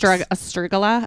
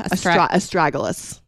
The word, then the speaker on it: Astragalus: Astragala? – Astragalus. – Astragalus.